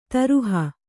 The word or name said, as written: ದ್ರೋಹಿ